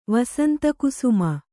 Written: ವಸಂತಕುಸುಮ

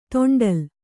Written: ದಂಡ್ಲೆ

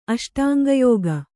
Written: ಅಷ್ಟಾಂಗಯೋಗ

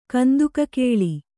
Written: ಕಂದುಕಕೇಳಿ

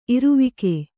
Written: ಇರುವಿಕೆ

